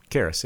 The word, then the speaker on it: Karras: Karras, yeah. – Karras? I was drawn towards... I think Father Karras is potentially the strongest character in this whole movie. – Fuck him in the ass, Karras.